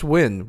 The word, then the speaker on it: Nguyen: It's just Nguyen, which I know, like, on paper doesn't make any sense. – Because the letters make it look longer than the actual pronunciation. – Yes, it does. But I think the problem is in Vietnamese, you would say, like, wing. And there's this, like, question and inflection.